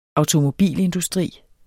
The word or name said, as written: automobilindustri